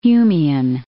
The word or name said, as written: Humian